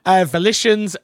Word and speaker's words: Volition: Volition? I've said that right. Right? – Volition, yeah.